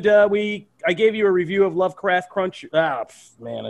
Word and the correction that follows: Lovecraft Country: Lovecraft Country last show,